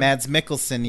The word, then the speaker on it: Mads Mikkelsen: And then and uh, and then Mads is it Mads Mikkelsen? – Mads Mikkelsen,